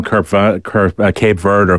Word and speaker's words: Cape Verde: I mean, we've all kind of became Cape Verde or Capo Verde fans, depending on how you want to pronounce it, Anton, over the last few weeks, mainly because of the story of Pico Lopez.